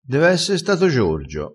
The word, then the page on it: dev esse: dialettale